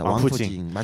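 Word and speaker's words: 王府井: 자, 또 다른 지역 설명을 해드리는데 한국어 발음으로는 왕부정이라는 곳이 있어요. – 아, 왕푸징. – 왕푸징입니다. 왕푸징 맞습니다.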